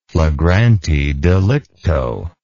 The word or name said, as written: flagrante delicto